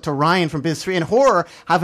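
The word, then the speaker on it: HO99O9: It's, what is it, H-O-9909, and it's pronounced Horror with Bone Collector.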